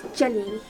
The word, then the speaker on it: Genie: His name is literally spelled G-E-N-I-E. It literally is pronounced Genie from Aladdin. – No, it's Jenny, like a girl name.